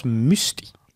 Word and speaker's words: Mustii: Ja, uitgesproken als Musti. – Musti. – Ja, M-U-S-T-I-I.